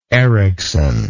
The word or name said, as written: Erikson